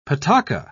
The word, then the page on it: pataca: British English